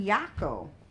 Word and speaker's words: Jaco: So, a 62-year-old expat, Thomas Cook, was found dead in Yaco. Yaco is spelled J-A-C-O. You know how I know how it's pronounced? That's where my mom and sister was Yaco.